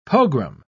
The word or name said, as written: pogrom